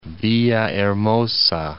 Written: Villahermosa

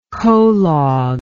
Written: colog

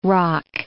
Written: roc

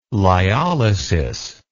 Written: lyolysis

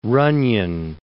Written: Runyon

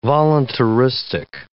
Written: voluntaristic

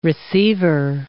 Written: receiver

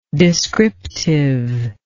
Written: descriptiveness

